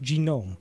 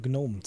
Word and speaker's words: GNOME: Also irgendwas Amerikanisches heißt Genome. Wie sollte man darauf kommen, dass die Leute das tatsächlich eher deutsch aussprechen und Gnome zu dem Ding sagen?